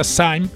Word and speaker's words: Sejm: O partido atualmente possui, nas últimas eleições, em 2019, legislativas, conseguiu 235 assentos de eh, deputados né, no SAIM, que é o SAIM, escreve-se S-E-J-M, mas a pronúncia é SAIM, que seria o, a Câmara Baixa, a Câmara dos Deputados lá, que tem 460 assentos.